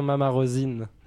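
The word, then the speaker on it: Mama Rosin: Et en fait, il n'y a personne qui prononce juste, ni les francophones, ni les anglophones. On est tout déprimés, alors on n'arrête pas de reprendre tout le monde, mais donc c'est bien Mama Rosine.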